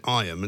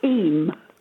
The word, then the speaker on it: Eyam: But the first thing I learned was how to pronounce it, because some people pronounce it Iam and some people pronounce it Eam. So I'm told Eam is correct. Is that right? Locals pronounce it Eam. – Oh, Eam, okay. So the Y is kind of silent. – Pardon? – The Y is a sort of silent Y.